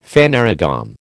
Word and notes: phanerogam: American English

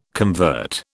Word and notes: convert: British English

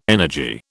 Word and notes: energy: British English

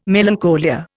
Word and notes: melancholia: Indian

